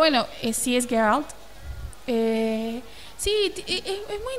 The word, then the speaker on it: Geralt: Pues se me acaban de corregir la pronunciación que es Geralt y es de Ribla.